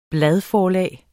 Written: bladforlag

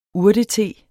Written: urtete

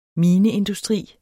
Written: mineindustri

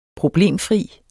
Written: problemfrit

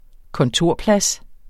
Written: kontorplads

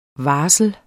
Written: varsel

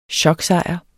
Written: choksejr

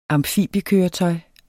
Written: amfibiekøretøj

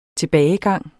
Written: tilbagegang

